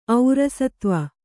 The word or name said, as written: ಔರಸತ್ವ